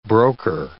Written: broker

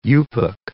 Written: Yupik

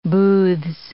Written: booths

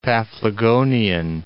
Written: Paphlagonian

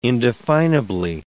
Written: indefinably

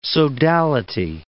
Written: sodality

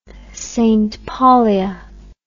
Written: saintpaulia

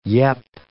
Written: yep

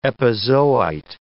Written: epizoite

